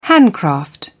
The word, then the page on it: handcraft: British English